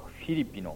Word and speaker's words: フィリピ: 新教徒はフィリピの信徒への手紙、これはギリシャ語に原点のですね発音に近づけようということでフィリピという発音になっております。